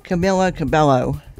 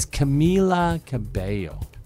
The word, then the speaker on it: Camila Cabello: Back in the day, before someone told me how to pronounce the two L's, I would have probably said Camilla Cabello. – Camilla Cabello. Yeah, well, that's kind of how it's spelled. Yeah. But it's Camila Cabello. Camila.